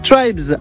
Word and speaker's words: TR3BZ: Un rendez-vous pas propre à ne pas manquer puisque Manuel Bonnefond reçoit Tribes, un groupe isoldunois. Ça s'écrit TR3BZ.